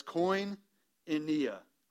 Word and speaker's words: koinonia: I'm going to try to make sure that I can say that correctly and help you say that correctly. But koinonia. All right, it's koinonia.